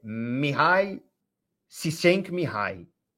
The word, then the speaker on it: Mihai Csikszentmihalyi: O nome dele é Mihai Csikszentmihalyi.